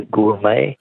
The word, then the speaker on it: gourmet: Would be a gourmet. – Gourmet, yes. Correct, G-O-U-R-M-E-T.